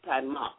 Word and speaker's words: Taimak: And it's pronounced Taima. Like a necktie, and like mock, putting on a mock election or a mock trial, you know. So you pronounce it Taimak, you know.